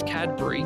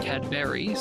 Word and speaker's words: Cadbury: First off, you're saying it wrong. It's Cadbury, not Cadbury.